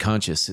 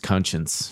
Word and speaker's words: conscience: You said conscious. It's conscience. Jesus Christ!